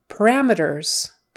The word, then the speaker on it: parameters: Syllable stress is on the second one. Parameters. And so meters, not meters. We wouldn't say parameters. It's one of those where we just kind of go meters, itters or utters, parameters. It's a non-stress syllable, so parameters.